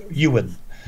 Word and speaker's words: Iwerne: It's spelled I-W-E-R-N-E, but pronounced Ewan.